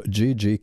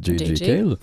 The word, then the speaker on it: J.J. Cale: Un album posthume pour J.J. Cale. En français, JJ, mais en anglais, on prononce J.J. Cale.